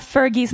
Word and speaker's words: Fergie: Om du uttalar Fergies namn fel en gång till. Det är ett G. – Är det ett G? – G. – G. Fergie.